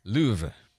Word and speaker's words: Louvre: Now say it right. That's not how the French say it. – That's Louvre. – Not quite. – No? Louvre. It's Louvre. – It's Louvre. That's it.